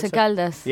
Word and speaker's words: Once Caldas: How do you pronounce this? Once Caldas? Once Caldas? The Colombian Once Caldas. – Yeah, there you go.